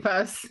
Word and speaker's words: Tsitsipas: And you mentioned Tsitsipas, is that how you pronounce his name? – Tsitsipas, yeah. Yes.